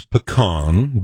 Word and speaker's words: pecan: I know the proper Utah pronunciation is pecan, but just to defend here, when Obama said, yes, we can.